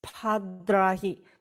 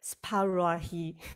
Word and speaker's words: spadrahi: 这个应该是念 spadrahi，可是我们只会，实际上我们只有念到 sparahi，哎，我我们。